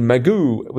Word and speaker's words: Magoo: M-C-G-O-O, Magoo. – Magoo. – But it's actually Magoo with an A, yep. More of an A sound.